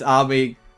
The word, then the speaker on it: Ami: I'm sorry, you just pronounced both of those wrong. – Ami. – Ami. It's Ami.